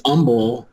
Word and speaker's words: Humble: And it's Humble. The H is silent. People get upset about that.